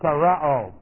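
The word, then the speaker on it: tereo (τηρέω): Here's the word, tereo. And this is T, long E, R, E, long O.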